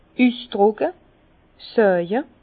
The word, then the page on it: üsdrùcke, saawe: Bas-Rhin; Herrlisheim